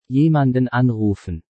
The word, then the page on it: jemanden anrufen: German